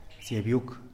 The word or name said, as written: psiabyuk